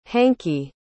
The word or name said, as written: hankey